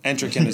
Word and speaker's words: Entrican: Attrition. Entrican is the correct pronunciation.